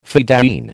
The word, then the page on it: fedayeen: American English